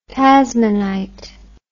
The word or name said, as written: tasmanite